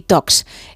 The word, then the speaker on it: Talks: Son charlas de tecnología, por eso Tech y Talks. Es que no se pronuncia la L.